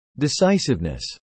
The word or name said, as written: decisiveness